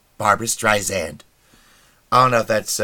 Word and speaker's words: Streisand: Streisand. Like sand.